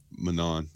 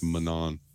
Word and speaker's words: Manon: The next note I have is the history of Menon. Am I pronouncing that correctly? – Menon. Spelled Manon. – Pronounced Menon.